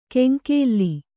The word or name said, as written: ಕೆಂಕೇಲ್ಲಿ